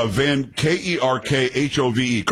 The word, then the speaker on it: Van Kerkhove: It's Van Kerkhove. Kerkhov. I'm pretty sure that's how you pronounce it.